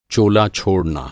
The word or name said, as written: चोला